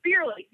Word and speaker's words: Beerly: It's called Beery, or maybe Beerly. Yeah, it rhymes with nearly.